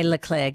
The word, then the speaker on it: Leclerc: Okay, Leclerc. Get it right, Deb. – Charles Leclerc.